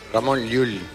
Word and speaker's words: Ramón Llull: Bueno, y yo lo entiendo porque pronunciar Llull, si no eres valenciano, balear, catalán, no se te da bien. – Difícil. – A ver, di Ramón Llull. – Ramón Llull. – ¿Ves? Llull.